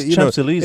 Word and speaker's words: Champs-Élysées: It's nice, Champs-Élysées.